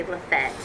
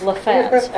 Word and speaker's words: Lafayette: or Lafayette. Did I say it right? I think they say Lafette. – Lafette.